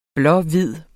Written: blå-hvid

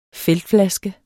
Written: feltflaske

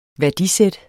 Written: værdisæt

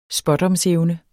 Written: spådomsevne

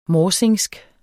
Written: morsingsk

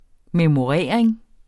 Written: memorering

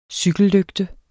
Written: cykellygte

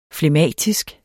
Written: flegmatisk

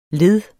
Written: led

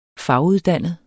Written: faguddannet

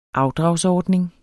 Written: AFDRAGSORDNING

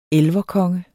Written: elverkonge

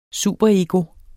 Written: superego

